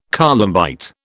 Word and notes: columbite: British English

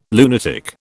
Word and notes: lunatic: British English

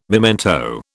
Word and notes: memento: British English

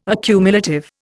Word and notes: accumulative: Indian

